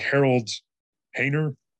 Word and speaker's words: Harold Hayner: Like Harold Hayner, again, I don't know if I'm saying that name correctly. – That's how I have heard his name pronounced, yeah.